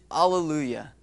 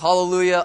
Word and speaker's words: Alleluia: I titled the message, Alleluia. It's kind of an interesting word. Sometimes pronounced hallelujah, alleluia, same word. One's from the Hebrew.